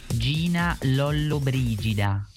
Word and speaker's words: Gina Lollobrigida: Gina Lollobrigida. – Lollobrigida. – Yeah, well, I think I said it correctly, just without the accent. – Lollobrigida.